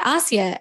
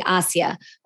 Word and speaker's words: Asya: And my name was, in fact, Asia, as I told her.